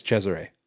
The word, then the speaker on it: Cesare: it could just be Caesar, too. Like... – yeah, well, I mean, the way that I know to pronounce that is Cesare. So, Let's go with that.